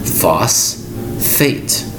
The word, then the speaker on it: phosphate: The word is phosphate, spelled P-H-O-S-P-H-A-T-E. You'll notice that P-H is used twice in the same word. It's pronounced phosphate. Now, you'll notice, you think there'd be an F in this word, but no. There's two times you'll see the expression used with PH. The PH is pronounced with an F sound. Again, the word is phosphate. P-H-O-S-P-H-A-T-E.